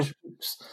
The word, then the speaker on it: Pepys: That's not spelled like that, though. – I know it's got a Y in it. Yeah, fair enough. But it's just pronounced Peeps, mate. Sorry. – Samuel P-E-P-Y-S, right.